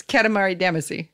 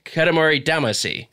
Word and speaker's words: Katamari Damacy: Katamari Damacy. – Katamari Damacy. – But I hear them saying like a she sound. But I guess that's just a slightly different pronunciation. – Yeah, it's a different phone.